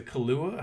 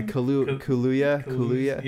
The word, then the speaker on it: Kaluuya: Man, initially I thought Daniel was a Kaluuya. How do you say his last name? Yeah, Kaluuya.